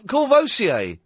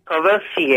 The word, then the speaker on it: Courvoisier: Coversol. – Oh, Courvoisier. – Courvoisier.